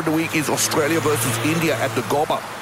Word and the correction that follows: Gabba: I think it's pronounced the Gabba.